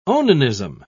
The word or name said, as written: onanism